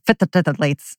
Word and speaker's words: phthalates: And phthalates is that word that looks like it's got a whole lot more syllables in it. It's like phthalates.